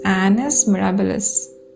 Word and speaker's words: Annus mirabilis: It's Annus mirabilis. I'm recording it again because I pronounced the word wrong before.